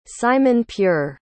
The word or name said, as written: simon-pure